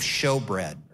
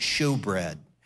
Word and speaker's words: shewbread: And the next thing that you would see as you journey through the tabernacle is you would see the table of showbread, or some people pronounce it shewbread.